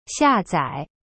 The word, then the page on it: 下载: xia zai